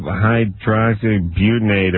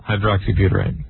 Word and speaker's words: hydroxybutyrate: Hydroxybutyrate.